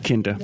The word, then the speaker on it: Kinda: Kenda is one. Or is it pronounced Kinda or Kenda? – Kinda. – Kinda.